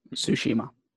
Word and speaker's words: Tsushima: I'm gonna get my ghost of Tsushima on. Is that what it's called? – Tsushima.